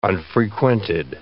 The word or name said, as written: unfrequented